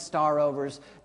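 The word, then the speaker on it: *overs: That's how we pronounce it, star overs.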